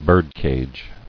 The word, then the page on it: Birdcage: bird·cage